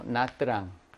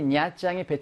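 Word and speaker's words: Nha Trang: 근데 남부에서의 발음은 이걸 냐짱 이렇게 해. 짱이라고. 짱이라고 들린단 말이야. 뜨랑이라고 분리해서. 근데 우리는 지금 북부 베트남 수도 하노이를 중심으로 하니까 거기서는 이것을 그냥 냐짱 이렇게 발음을 하거든요.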